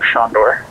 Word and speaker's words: Sandor: My name's Shondor. – How do you spell that? – That's S-A-N-D-O-R.